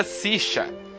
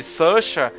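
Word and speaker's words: Saoirse: Diz que o nome dela é em, irlandês se pronuncia Sisha. Mas ela fala esse Susha é, quando ela tá no, nos Estados Unidos, entendeu? Mas assim, no irlandês, o nome dela, o nome dela se pronuncia Sisha. Mas foda-se, eu chamo, eu chamo ela de Saorze, que é mais, é mais bonito.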